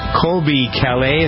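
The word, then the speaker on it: Colbie Caillat: Let's number 8 this week on our list is Colby Calais.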